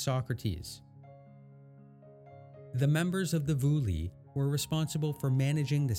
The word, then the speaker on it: Boule: To start, we can note the existence of the Vouli, which is spelt B-O-U-L-E.